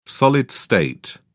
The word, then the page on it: solid-state: British English